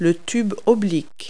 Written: downtube

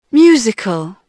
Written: musical

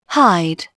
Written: hide

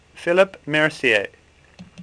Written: Mercier

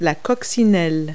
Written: ladybug

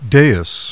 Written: DAIS

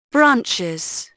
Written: branches

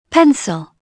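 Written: pencil

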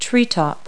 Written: treetop

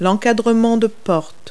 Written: doorway

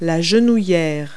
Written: kneepad

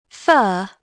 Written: fur

